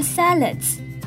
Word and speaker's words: salads: 而第二个发音重点呢是 salads。 salads 要注意的是两个 A 的发音不同。第一个是发蝴蝶音 a，而第二个则是发 a 的音。